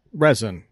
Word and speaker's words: rosin: Or is it pronounced R-O-S-I-N? – Resin. – Resin or rosin? – It's resin. – Resin. – Yeah, but is there also an R-E-S-I-N?